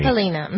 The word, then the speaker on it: Halyna: That it's been a year since Halyna, that's how we pronounce her name, you'll keep correcting me. – Halyna.